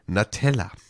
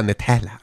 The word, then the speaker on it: Andrea Natella: Natella, ich habe das so schön italienisch ausgesprochen. Andrea Natella, natürlich. – Natella. Sagen, ne? – Natella. – Genau. Die Nutella nur mit A quasi. Und U, also ohne U mit A. Genau.